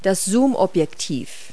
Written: zoom lens

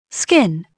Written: skin

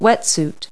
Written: wetsuit

